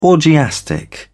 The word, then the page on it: orgiastic: British English